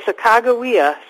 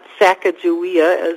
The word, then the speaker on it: Sacagawea: Okay, Sakagawea, speaking of history, that is how her name was pronounced, they believe, not Sacagawea, as many people pronounce it.